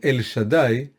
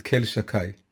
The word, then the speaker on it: El Shaddai: It's called El Shaddai, but we pronounce it Kel Shakai.